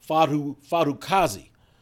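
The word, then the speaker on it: Fatukasi: They also got more athletic in their front seven altogether with the additions of not only Trevon Walker, up front, and I'm going to say this name correctly, hopefully this time, because I've been told I've been mispronouncing it. Fadukazi, the big defensive tackle that they got from the Jets.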